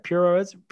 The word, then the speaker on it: puroresu: How do you say that? Puro resu. Puroresu.